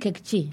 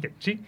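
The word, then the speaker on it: Q'eqchi': En territorio maya, aquí me ayudarás a la pronunciación, Kekchi. – Kekchi. – Kekchi.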